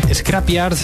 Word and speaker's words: Scrapyard: Podemos leer en el blog de nuestros amigos de Electronic and Roll como el colectivo Scrapyard, a ver si lo digo bien, es Scrapyard, vuelve a la sala Speca con una fiesta que no te debes perder.